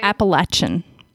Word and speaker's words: Appalachian: so the Appalachian. Am I saying that right? – Appalachian.